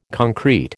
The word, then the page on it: concrete: American English